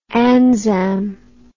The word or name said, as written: ANZAM